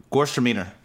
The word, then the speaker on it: Gewürztraminer: Oh, Gewürztraminer.